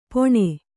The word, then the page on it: ಪೊಣೆ: poṇe